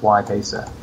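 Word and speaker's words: Y-Pacer: It's just Y Pacer.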